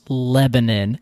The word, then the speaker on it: Lebanon: And I was like, hey, what's up, Lebanon? The DJ literally took the microphone from me and was like, it's pronounced Lebanon. And I was just up on stage.